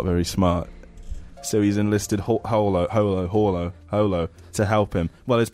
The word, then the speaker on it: Holo: Holo. Is it pronounced like that? – Yeah, Holo.